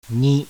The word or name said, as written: NI